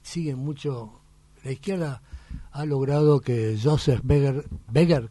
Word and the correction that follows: Goebbels: como siempre lo pronuncio mal, el alemán no entiendo un pito, el famoso publicista que tuvo Hitler. – Goebbels. – ¿Cómo? – Joseph Goebbels.